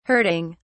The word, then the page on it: hurting: British English